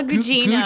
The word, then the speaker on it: Gugino: Carla Gugino. Gugino.